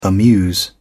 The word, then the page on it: amuse: British English